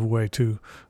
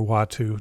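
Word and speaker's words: Uatu: There was usually not any sort of factor that tied everything together, with the exception of Watu, or Watu, depending upon how you want to pronounce it, the Watcher, that narrated the stories and was talking directly to the reader.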